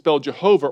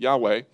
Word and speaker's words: Yahweh: And so the way that you spell Jehovah or Yahweh, which is the way I pronounce it, Yahweh, is Y-H-W-H. Those four letters, so it's called the Tetragrammaton. And some people pronounce that Jehovah.